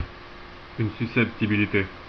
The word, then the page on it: susceptibilité: France, Paris; [yn sy.sɛp.ti.bi.li.te]